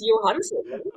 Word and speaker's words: Johansson: Well, you say Scarlett Johansson, so I don't know. – What are you supposed to say, Johansson? – It's Johansson. – No, really? Johansson. Oh. Yeah, that's how she pronounces it.